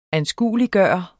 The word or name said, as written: ANSKUELIGGJORT